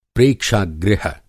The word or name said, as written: प्रेक्षा-गृह